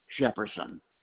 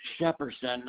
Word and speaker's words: Shepperson: George Shepherdson. – All right. George is G-E-O-R-G-E. Jefferson, J-E-F-F-E-R-S-O-N. – No, it's Shepherdson, not Jefferson. – Can you spell that for me? – Okay, S-H-E-P-P-E-R-S-O-N.